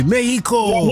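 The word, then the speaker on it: México: When you get there, don't pronounce the X the way that we do. Just say Mexico.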